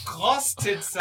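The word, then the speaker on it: Ur-Krostitzer: Ich pack Köstritzer und Urkostritzer? – Nein, Krostitzer.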